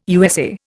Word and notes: USA: Indian

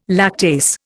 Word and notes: lactase: Indian

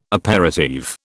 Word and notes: aperitif: British English

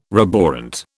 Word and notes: roborant: British English

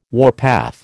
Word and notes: warpath: American English

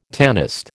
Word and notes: tanist: American English

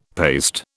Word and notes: paste: British English

